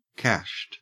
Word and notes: cashed: Australian English

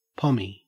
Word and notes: pommy: Australian English

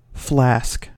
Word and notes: flask: American English

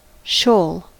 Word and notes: shoal: American English

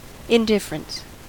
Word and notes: indifference: American English

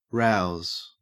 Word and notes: rouse: Australian English

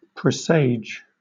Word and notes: presage: British English

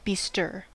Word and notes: bestir: American English

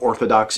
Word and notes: orthodox: American English

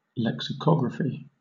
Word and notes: lexicography: British English

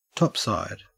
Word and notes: topside: Australian English

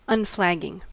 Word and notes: unflagging: American English

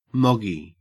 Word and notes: moggy: Australian English

